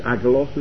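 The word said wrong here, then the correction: Angelos (ἄγγελος): is it? – Angelos. – There we go.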